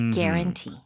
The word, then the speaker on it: guarantee: 처음에 GA가 아니라 GUA예요. – 네, 근데 발음은 그냥 care, guarantee.